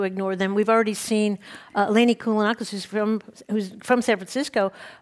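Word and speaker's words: Eleni Kounalakis: Eleni Kunalakis.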